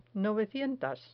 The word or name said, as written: novecientas